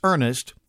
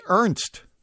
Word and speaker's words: Ernest: And you'd think it'd be easy because it's Ernest. E-R-N-E-S-T. Ernest. But I know some people would say Ernst. My grandma would have called him Ernst. I had an uncle Ernie, and he was Ernest.